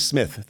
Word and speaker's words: Psmith: Woodhouse novels is Leave It to Smith, spelled P-S-M-I-T-H. – He would always say, my name is Smith, the P is silent.